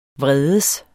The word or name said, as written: vredes